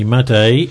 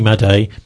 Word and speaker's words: Madej: Okay, so there she was near the end of that, Carrie Madde. That's at least she pronounces her name right. I got it all wrong, but that's Carrie Madde. The spelling is MADEJ.